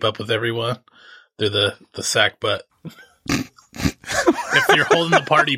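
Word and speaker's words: sackbut: It's pronounced sack butt. I think it's spelled S-A-G-B-U-T.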